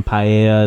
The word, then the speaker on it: paella: So people are making fucking paella and like nice Spanish dishes. – There you go. Yeah. Good pronunciation.